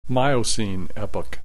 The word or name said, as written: Miocene Epoch